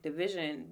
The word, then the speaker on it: DVSN: Am I saying your name right? – DVSN. – Division. Oh!